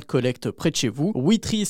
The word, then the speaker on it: Wetri: Merci beaucoup Arnaud Louiset pour cette découverte de l'application Witry.